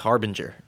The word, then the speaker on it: harbinger: Is that the right pronunciation? Harbinger? – I think it's harbinger, yeah.